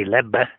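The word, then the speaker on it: Lebbe: His last surname is spelled L-E-B-B-E. I think in Flemish, which it's a Flemish name, it would be Leb, but he was a French speaker and they apparently had just the one syllable Leb.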